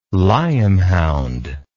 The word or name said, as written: lyamhound